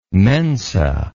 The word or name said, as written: mensa